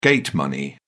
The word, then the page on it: gate money: British English